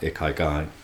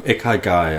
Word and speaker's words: Ikigai: I'm probably saying it wrong. Ikigai, I think it's pronounced. I-K-I-G-A-I.